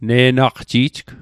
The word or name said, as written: ne'naqji'jg